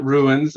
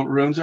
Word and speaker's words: runes: Yeah, I do hear a lot of people pronounce it ruins, and I always kind of fun in a fun way say, you know, runes are not broken stones. it is runes.